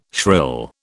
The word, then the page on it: shrill: British English